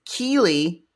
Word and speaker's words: Keely: So for Ren, it was Cammy and Kylie. – I think it's Keenly. Keely. – I just heard a K thing and I went, King, King, King. – It is Keely and Keely.